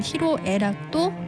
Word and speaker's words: 희로애락: 또 기쁨과 노여움, 슬픔과 즐거움을 뜻하는 희로애락도 마찬가지인데요. 여기서 로는 원래 성내 노라는 한자거든요. 분노에서는 노로 읽고 쓰지만 희로애락에서는 로로 읽고 쓰는 거죠.